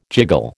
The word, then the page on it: jiggle: American English